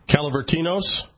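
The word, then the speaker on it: Kalavritinos: Calavritinos? – Oh, my gosh, yes. I mean, that sounds like the Greek pronunciation, yeah.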